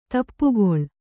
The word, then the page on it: ದಾಪುಗಾಲು: dāpugālu